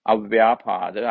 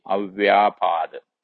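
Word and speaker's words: avyāpāda: And the second one is avyapada. I will just spell it out: AVYAPA. Long A D A A V Y A long A P A long A D A Avyapada.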